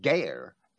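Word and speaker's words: geyr: And therefore, there is a derivative, Strong's number 1616, and I'll pronounce that geir. It's G-E-Y-R in Strong's English transliteration.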